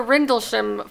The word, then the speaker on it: Rendlesham: Love the Rendlesham Forest episode with our strange skies.